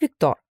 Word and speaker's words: Виктор: Зовут Виктор. Именно с ударением на «о».